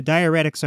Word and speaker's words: diuretics: So they started her on, I don't know how to pronounce this either, diuretics? – Yeah, diuretics are medicines that drain fluids.